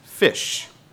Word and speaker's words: ghoti: You'll see that G-H-O-T-I is actually pronounced fish.